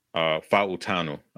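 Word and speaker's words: Fautanu: My number three guy is Troy Fontenot. Fautano, I believe that's how you say it, from Washington.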